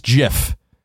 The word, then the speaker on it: GIF: The creators of the format pronounce GIF as GIF, with a short G as in gin.